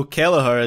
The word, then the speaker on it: Kelleher: Is it Kelleher or is it just Keller? – Oh, so Kelleher is correct, but I have no idea on the first name.